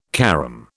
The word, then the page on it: carom: British English